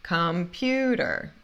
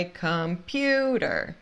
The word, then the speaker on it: computer: Não é computer, é computer. Computer, ok? Por quê? Esse piu, piu, a segunda sílaba é a sílaba tônica, então é computer, computer.